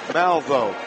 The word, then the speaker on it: Malveaux: Being sponsored by LaCal Equipment is Jair Malvo, J-H-I-E-A-R, pronounced Jair, last name M-A-L-V-E-A-U-X.